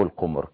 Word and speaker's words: القمر: جزر القمر او القمر كما ينطقها البعض، ونسأل ضيفنا حول هذا.